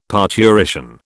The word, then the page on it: parturition: British English